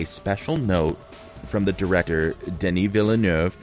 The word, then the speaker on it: Denis Villeneuve: That's how you pronounce his name. – Yes, exactly. – It looks like Denis Villeneuve. – Denis Villeneuve. – Like Villa and Nerve, but you drop the R.